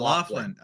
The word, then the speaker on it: Laughlin: it's spelled like laugh and L-I-N, but it's pronounced Laughlin.